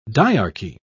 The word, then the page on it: diarchy: British English